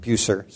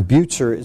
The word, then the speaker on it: Bucer: It was in Strasbourg that he met a man by the name of Martin Bucer, and this is pronounced Bucer rather than Bucer. So Bucer, it's like it's B-U-T-Z-E-R.